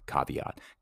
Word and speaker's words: caveat: It's a Jessup subjunctive, so I'll have to figure out the exact correct translation from caveat. Caveat, remember, because V's are pronounced as W's.